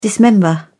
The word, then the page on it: dismember: British English